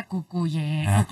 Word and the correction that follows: Cucurella: Ya no puedo. Cucurella.